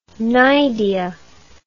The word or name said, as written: cnidia